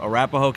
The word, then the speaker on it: Arapahoe: Eric is running for sheriff in Arapahoe County. Did I say that right? Yep, Arapahoe County, Colorado.